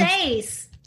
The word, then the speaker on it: seis: I it's not authentic if I say it like numero seis, which is Spanish for number seis. Seis, right? Seis, like S-A-Y-S, seis. How's it really S-E-I-S. – Spelled? – S-E-I-S. – I knew that. Seis.